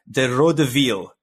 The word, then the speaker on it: De Røde Hvide: I've been practicing my Danish pronunciation, so I think it's pronounced De Røde Hvide, which is translated to the red and whites, which I find that latter term much easier to say.